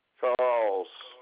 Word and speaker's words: Charles: J-U-D-Y. – Hey, can I get your first name again? – Charles. – Spell that for me. – C-H-A-R-L-E-S.